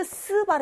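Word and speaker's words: 스 (四): 시인가요? 아니죠. 시 아니잖아요, 여기. 즈, 츠, 스 발음이잖아요. 이것도 많이 틀립니다. 이얼산 시가 아니라 이얼산 스잖아요. 스. 사성이에요.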